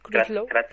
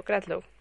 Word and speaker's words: Crutchlow: Sí, Crutchlow. Eso, Crutchlow.